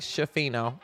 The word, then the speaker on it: Schifino: So from the looks of this Hood Schifino video, which now I'm saying his name right, Schifino, it looks like he'll be great coming off of the screens.